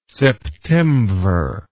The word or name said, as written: septemvir